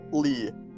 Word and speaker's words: Leigh: Lee.